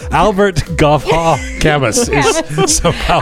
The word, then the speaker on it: Camus: Hearing the happy voice of Reza Rouhani talking about harmony, not melody, reminded me of this quote from Albert Camus." He says, Camus. – Oh, pardon me. Again, with the pronunciation.